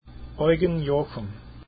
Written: jochum

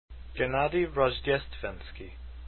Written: rozhdestvensky

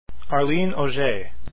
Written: auger

